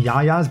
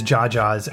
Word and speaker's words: Jaja's: So, but they did tell me it is Jaja's, as it is pronounced in the show multiple times.